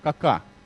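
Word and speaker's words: Kaká: Это бразилец Кака, ударение именно, вот как я сказал, да.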